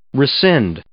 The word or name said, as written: rescind